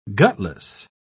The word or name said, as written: gutless